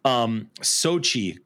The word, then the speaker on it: Xochitl: Sochi Gomez. Sochi Gomez is her name. – All right. – Forgive me. It's spelled Xochitl.